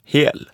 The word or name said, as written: hel